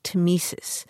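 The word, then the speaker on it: tmesis: That's T-M-E-S-I-S, temesis.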